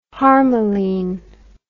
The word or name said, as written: harmaline